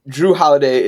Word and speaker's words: Jrue Holiday: And um, I just mean I mean Jrue Holiday is a clear upgrade over over Eric Bledsoe.